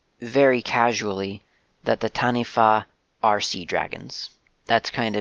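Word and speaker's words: taniwha: Historically, in legends that you will hear of the Tanifa, you have stories about So first of all, I should say Tanifa is spelled, if you're looking for it online for more information, it's T-A-N-I-W-H-A. The W-H in transliterated Māori tongue is pronounced as an F, so Tanifa.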